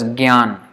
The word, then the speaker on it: jnana: In usually in many Indian languages, in Hindi and Marathi and Gujarati, it is pronounced as gyan, like G, gyan. But in Sanskrit, that is wrong. But you, if you pronounce it like jnana or like a G sound, it is not really wrong because of Marathi. But if you want to be pure Sanskrit, it is wrong.